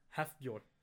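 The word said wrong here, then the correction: Hafþór: I think it's pronounced Hafthor.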